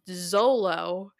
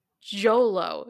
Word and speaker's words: Xolo: I, I watch YouTube videos because I knew I was going to mess it up because I was pronouncing it Zolo, and it's like Jolo, Jolo Maridueña, and it's actually n- Nahuatl, Nahuatl language, which is an oh.